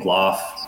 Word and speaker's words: Gloff: So Facebook under my name, Nick Gloff, spelled phonetically, nothing weird.